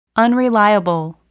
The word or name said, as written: unreliable